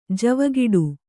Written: ಜವುಗಿಡು

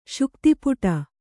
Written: ಶುಕ್ತಿಪುಟ

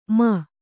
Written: ಮೇ

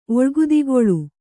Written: ಒಳ್ಗುದಿಗೊಳು

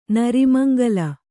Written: ನರಿಮಂಗಲ

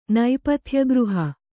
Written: ನೇಪಥ್ಯಗೃಹ

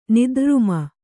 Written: ನಿದ್ರ್ರುಮ

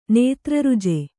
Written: ನೇತ್ರರುಜೆ

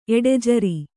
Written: ಎಡೆಜರಿ

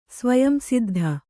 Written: ಸ್ವಯಂಸಿದ್ಧ

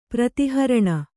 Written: ಪ್ರತಿಹರಣ

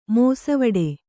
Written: ಮೋಸವಡೆ

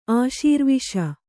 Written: ಆಶೀರ್ವಿಷ

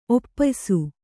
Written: ಒಪ್ಪಯಿಸು

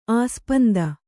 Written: ಆಸ್ಪಂದ

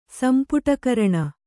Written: ಸಂಪುಟಕರಣ

